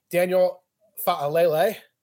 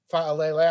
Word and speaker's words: Fa'alele: Daniel Fa'alele. – Very good. Very good. – Fa'alele.